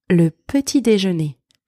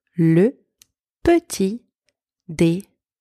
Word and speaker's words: le petit déjeuner: Le petit déjeuner. That's breakfast. Le petit déjeuner.